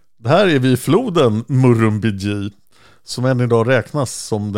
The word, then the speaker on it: Murrumbidgee: Och jag fick en um, ut uttalsanvisning. Murrumbidgee, ja.